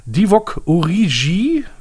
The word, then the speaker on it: Divock Origi: Einer, der Hoffnungsträger sein soll und gekommen ist vom FC Liverpool, ist ja Divock Origi, wie ich mir habe sagen lassen, dass es ausgesprochen wird.